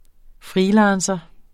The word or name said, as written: FREELANCERE